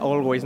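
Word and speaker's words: Alvvays: ¿por qué se escribe con W? pero se pronuncia always, ¿no?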